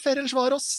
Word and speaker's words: Ferencváros: Hvordan uttaler du egentlig Ferencváros, Asbjørn? For det skal leses som Ferencváros, ifølge Mathias.